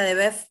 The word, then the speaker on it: de Boeuf: Sí, bien, me pueden ubicar en Facebook como Sandra Beatriz Ati, que es A-T-Y y mi apellido se pronuncia de Bef, pero se escribe D-E separado B larga O-E-U-F. En Instagram estoy como Sandra de Boeuf y también tengo otro Instagram que es por donde hablo mucho sobre plantas y flores que se llama La Yullería Tinturas.